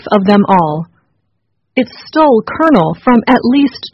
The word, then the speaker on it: colonel: Kernel is spelled C-O-L-O-N-E-L. So why do Americans say kernel as if it is spelled K-E-R-N-E-L?